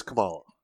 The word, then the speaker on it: Kamala: However you say her name. – I believe it's Kamala.